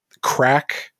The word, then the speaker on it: craic: So have you ever heard the term crack? Like, it's spelled C-R-A-I-C, and it's in a sentence.